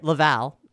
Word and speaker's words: Laval: John Lavelle Sorry, Laval. – That's okay. – I have a long history of saying your name wrong.